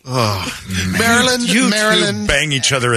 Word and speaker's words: Maryland: It is said as Maryland, Maryland. Stress on the first syllable.